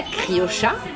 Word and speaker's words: criolla: Alors, il faut, savoir que les Argentins prononcent le che et pas le yeux, donc on dit la criocha.